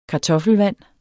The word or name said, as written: kartoffelvand